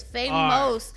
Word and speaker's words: Fameos: It's Fameos.